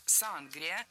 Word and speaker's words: сангрия: На маяке у нас сангрия. Я сама слышала чаще вариант сангрия, а филологи рекомендуют говорить сангрия с ударением на втором слоге.